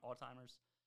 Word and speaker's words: Alzheimer's: Is that how you say it? – mm-hmm. – Alzheimer's. But he didn't have any of that, but he was still losing his train of thought.